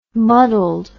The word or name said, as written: muddled